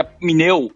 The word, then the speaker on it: pneu: Por exemplo, pneu, que o correto seria pneu, porque o P é mudo, uhum.